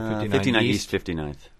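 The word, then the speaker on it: 59E59 Theaters: My guests are two members of the cast of a new play called Opus that's at, how do you say, 59E 59 Theaters? Or 59 East 59th.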